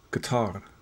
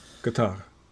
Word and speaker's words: Qatar: Somehow Qatar, which apparently is correctly pronounced Qatar. – Qatar.